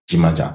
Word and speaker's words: Jimaja: Vous avez réussi à insister sur le J qui se prononce Dja, Dimaja.